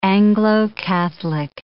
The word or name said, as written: Anglo-Catholic